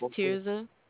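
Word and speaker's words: Tirzah: My name is Tirza.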